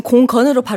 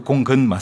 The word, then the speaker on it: 공근: 그, 아, 지금 공근으로 발음하신 예, 거예요? – 그 공근 맞습니다. – 공근이라고 들리는데요. – 공군이라고 들렸나요? – 아, 예.